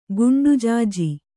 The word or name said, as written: ಗುಂಡುಜಾಜಿ